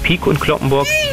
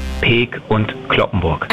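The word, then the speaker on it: Peek & Cloppenburg: Es ist die Firma Peek und Kloppenburg. Nicht Peek und Kloppenburg. Die korrekte Aussprache heißt Peek und Kloppenburg.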